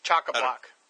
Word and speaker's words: chock-a-block: This book is chock-a-lock. How do you say it? Chock-a-lock? What do you say? – Chock-a-block.